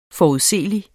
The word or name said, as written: forudseelig